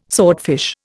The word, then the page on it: swordfish: Indian